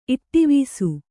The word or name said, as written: ಇಟ್ಟಿವೀಸು